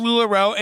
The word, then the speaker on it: LuLaRoe: Well, she's taking what happened with LuLaRoe. Did I say it right? – Yeah, but who cares? – Okay. She's taking what happened with LuLaRoe, and she's building backwards to make a mythology.